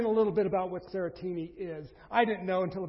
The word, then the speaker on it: serotiny: I pronounce it Serotiny. Or Serotiny. Actually, it's Serotiny. I know some of you hillbillies probably say Serotiny, but the sophisticated folks say Serotiny.